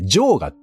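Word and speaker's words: 嫦娥: はいはい、ちょっとスマホで打ったんで、女が常、で、それから女に我と書いて、ジョーガって読むんですけど、ジョーガに濡れながら、浜田雅俊と話してました。ジョーガが分かんない人はね、一応補足ね、ジョーガっていうのは月のことです。私が分かって書いたかどうか知りません。ムーンですね。はい。ジョーガに濡れながら浜田雅俊と話していると、笑いながら野生爆弾クッキーが録音機材を持って僕の手前に置きました。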